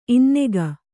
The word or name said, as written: ಇನ್ನಕ